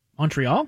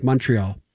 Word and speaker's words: Montreal: Montreal? – You're wrong. It's Montreal. – Mun with a U. – Mun. Yeah, that's how you say it.